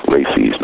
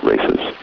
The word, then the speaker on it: RACES: Pronounced RACIs, not races, is the Radio Amateur Civil Emergency Service.